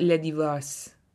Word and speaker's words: Le Divorce: Do we have anything left to say about Le Divorce? How's my pronunciation, by the way? – It's actually pretty good.